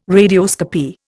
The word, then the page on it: radioscopy: Indian